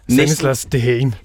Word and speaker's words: Stanislas Dehaene: ved navn Stanislas Dehaene. Er det rigtigt udtalt? – Det er næsten rigtigt. Stanislas Dehaene.